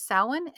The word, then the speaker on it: Samhain: So I'll just sum it up by saying that Sam, S-A-M, which we pronounce as sow, is summer, and hen or an comes from the Irish word, which is spelled F-U-I-N, meaning end. So what is Samhain? In its most basic sense, Samhain is summer's end.